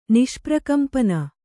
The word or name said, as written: ನಿಷ್ಪ್ರಕಂಪನ